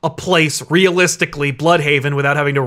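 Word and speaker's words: Blüdhaven: DC confirms that Bloodhaven has been pronounced wrong this whole time. It's Bloodhaven. – Well, that's what the umlaut means. It means bleh.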